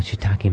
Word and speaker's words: shiitake: To do anything with shiitake mushrooms to me is a kick.